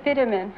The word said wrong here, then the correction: Fenneman: Mr. Fiddeman? You mean Fenneman? – That's right.